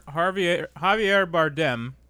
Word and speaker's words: Javier Bardem: I hope I said that right. I don't know if I said it right. – Yeah, Javier Bardem. – Okay, good. Whew. – Who does Oscar winner Javier Bardem play in the movie Skyfall? – There we go.